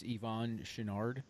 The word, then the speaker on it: Yvon Chouinard: It's Yvon Chouinard.